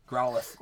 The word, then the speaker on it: Growlithe: Is it Growlithe? – Growlithe. – Growlithe? Yeah, that one.